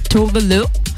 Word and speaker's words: Tove Lo: She's Swedish and she said in an interview that her name is actually pronounced as Tove Lo.